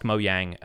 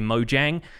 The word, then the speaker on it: Mojang: in which I'm pretty sure that they confirmed that the canonical pronunciation is actually Mojang, and they just say it Mojang because they're Swedish, but it's supposed to be Mojang, according to Mark Watson, who was on that video.